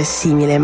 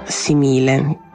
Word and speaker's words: simile: È per questo che a un certo punto la parola simile non si dovrebbe leggere simile ma simile, con l'accento piano sulla seconda sillaba.